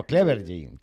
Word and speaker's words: Clevergy: Pero bueno, Clevergy. Que además se pronuncia tal cual, ¿no? Porque estas cosas yo del inglés... – Lo he dicho perfecto.